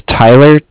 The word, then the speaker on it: Tyler: My name is Tyler, T-Y-L-E-R. And I'm calling... – T-Y-L-E-R? – Correct, T-Y-L-E-R.